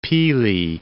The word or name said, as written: Pelee Island